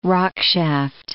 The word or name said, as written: rockshaft